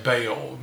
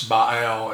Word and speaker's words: Baal Zephon: We learned growing up to pronounce, say, Baal, you know, as the demonic god, but it's Baal and it's Zephon.